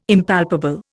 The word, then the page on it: Impalpable: Indian